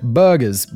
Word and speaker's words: burghers: Those settlers now refer to themselves as burghers, like the food.